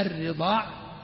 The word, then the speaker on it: الرضاع: إذن يضبط بفتح الراء وكسرها المشددة الرضاع والرضاع، مع أن الفتح أكثر وأفصح.